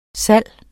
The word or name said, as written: salgs